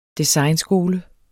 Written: designskole

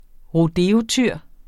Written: rodeotyr

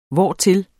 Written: hvortil

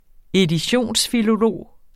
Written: editionsfilolog

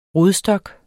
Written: rodstok

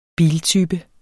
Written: biltype

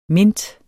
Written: mint

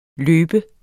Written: løbe

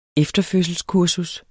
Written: efterfødselskursus

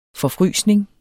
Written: forfrysning

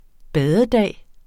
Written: badedag

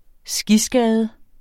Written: skiskade

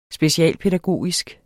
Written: specialpædagogisk

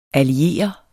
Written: alliere

